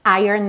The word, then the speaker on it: Iron: Eu achei super bacana já informar para você para quando você falar sobre essa banda você já fazer a pronúncia certinha Iron Maiden. E não só com o nome da banda, mas toda vez que você falar essa palavra ferro, inclusive o homem de ferro também vai ser Iron Man, alright?